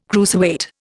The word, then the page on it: cruiserweight: Indian